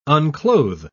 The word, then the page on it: unclothe: British English